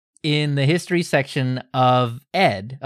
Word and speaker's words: ed: So a few folks definitely wanted to point out that the text editor ED is indeed pronounced E-D. – Got it. Ed. Oh, wait, no, me E-D.